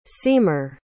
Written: seamer

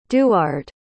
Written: duard

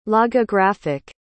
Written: logographic

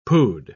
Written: pood

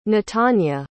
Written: natania

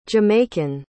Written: jamaican

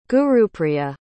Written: gurupriya

